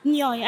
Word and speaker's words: Nyoy: Noela Nyoy. Also Noela N-Y-O-Y.